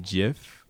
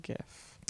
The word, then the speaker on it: GIF: Because it's GIF and then JIF. Mm-hmm. Because they're like, you don't say Jaffix. – Right. – You know, it's graphics. – GIF.